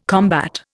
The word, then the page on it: combat: Indian